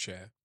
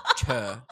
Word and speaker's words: Cher: There's one correct way, and it's Cher. – Cher.